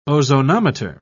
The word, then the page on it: ozonometer: British English